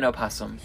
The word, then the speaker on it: opossum: Yeah, human opossum. – You what? – Human opossum. – Human opossum. Yeah, okay. Right? – Am I supposed to pronounce the O? – Yes.